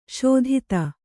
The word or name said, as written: ಷಾತೂದು